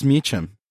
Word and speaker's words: Meacham: And we're here with a friend of the show, Bruce Meacham.